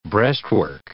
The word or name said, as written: breastwork